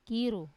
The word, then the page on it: kiru: wamesa